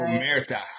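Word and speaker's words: omertà: Omerta.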